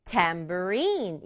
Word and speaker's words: tambourine: 그러면 no, it's a 탬버린은 tambourine 이렇게 발음해요.